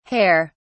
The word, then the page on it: hare(s): British English